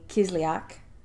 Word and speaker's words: Kislyak: Well, he ignored questions about what exactly he told Russian Foreign Minister Sergei Lavrov and Ambassador Sergei Kislyak.